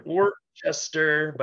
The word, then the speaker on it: Worcester: Worcester, but it's pronounced Worcester, I guess.